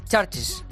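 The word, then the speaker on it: CHVRCHES: Desde Glasgow, en el Reino Unido, llegan, a ver, yo digo Churches, ¿vale? Pero esa U que yo pronuncio es una V. Y además creo que se pronuncia Churches.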